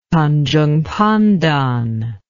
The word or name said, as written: Tanjung Pandan